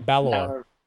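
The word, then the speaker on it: Bálor: Balor. – Balor, thank you. I don't mean to mispronounce. I was just excited. I put the wrong syllable on the...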